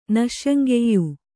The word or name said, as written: ನಸ್ಯಂಗೆಯ್